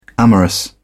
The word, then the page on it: amorous: British English